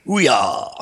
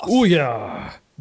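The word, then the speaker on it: Ouya: How do I pronounce it? – Ouyah. – Ouyah.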